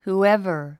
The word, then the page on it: whoever: huːévə r; フゥエバー